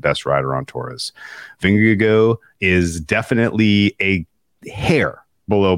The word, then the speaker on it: Vingegaard: I've settled on Vingegaard as the pronunciation. Like, it's like the G-E-A-U, like Go Tigers LSU. Yeah. Vingegaard.